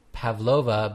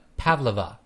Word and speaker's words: Pavlova: The stress is in the first syllable, not Pavlova, but Pavlova.